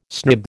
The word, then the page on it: snib: American English